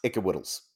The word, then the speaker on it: IKWYDLS: Yes, I believe it's pronounced Ickawiddles.